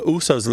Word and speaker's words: Uso: So you might say all the USOs love to eat at that restaurant, meaning Samoans love to eat at that restaurant. And it's shortened to US, to rhyme with moose or goose.